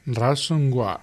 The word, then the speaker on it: ransomware: ¿Cómo es? ¿Cómo se pronuncia? – Ransomware.